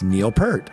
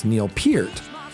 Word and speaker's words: Peart: And I think I told you earlier, I've been mispronouncing the drummer's name my entire life. – And I've been saying it's Neil Peart. I hear it on the radio. I hear whatever. Oh, that's Neil Peart. The way that Geddy pronounces it is it's Neil Peart. So um, I, I, stand corrected.